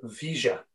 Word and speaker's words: Villa: In Ozzy Ardiles and Ricardo Vigia, I think is the correct pronunciation. – Yeah, well done.